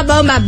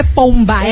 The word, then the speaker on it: bomba: Então, ó, manda aí o emoji, Bomba Bomba, bomba, bomba. – Bomba não é pomba, é, é bomba. Bomba. Bomba que B-O-M.